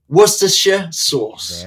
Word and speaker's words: Worcestershire sauce: That's how you say it, too. – Say it again. – Worcestershire sauce.